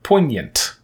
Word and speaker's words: poignant: This adjective meaning arousing emotions comes from a French word for pointed, and he gets that correct with poignant. Mm-hmm. Is that really a silent G? – It makes the N say nya instead yeah. of na.